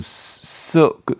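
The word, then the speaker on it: Silk: 근데 그, 어, 실크, 크 발음을 거의 안, 안 하는 경향이 있거든요. 그래서, 썩, 그 크가 끝에 살짝, 썩, 약간 요 정도로 붙어 있고, 소닉은 오는 아 발음을 하는 경우가 많아요.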